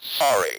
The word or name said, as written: sorry